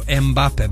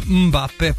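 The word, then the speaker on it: Mbappé: Ne volim kada kažu Mbappe, brate, on sam sebe ne zove Mbappe, dakle on je Mbappe, pa ako ne umete da izgovorite, nemojte ni da izgovarate.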